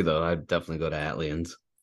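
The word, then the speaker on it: ATLiens: And now I know it's supposed to be like Atlanta, ATL, but is it pronounced Atlians? – Atlians, yeah. – Atlians. Okay, that's what I thought.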